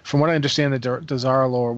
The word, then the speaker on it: Dazar'alore: I want to say Darzalore. Is that how you pronounce that? – Darzalore. I don't know. – Dazar'alore. – Dazar'alore. Thank you.